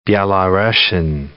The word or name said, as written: Belarussian